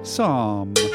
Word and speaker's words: psalm: Minotaur mummy, psalm. Whoa, he's in there. Yeah. He knew there was a silent P coming.